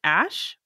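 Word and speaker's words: Æ: And apparently the AE is an ash. It's pronounced ash for some reason.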